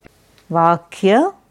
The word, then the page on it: Vakya: Sanskrit